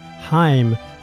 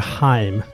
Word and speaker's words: Haim: Men jag ska börja med något osnyggt, nämligen att vi uttalade gruppen Heim fel. Vi pratade om dem i samband med Licorice Pizza. Och de heter ju Haim, det är hebreiska, och inte Heim som vi sa.